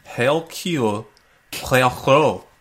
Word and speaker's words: Hercule Poirot: Hercule Poirot, and I will deliver your killer. That's how he says it, man. Get it right. Come on. Get your French up.